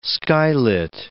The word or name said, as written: skylit